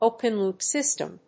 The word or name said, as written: open loop system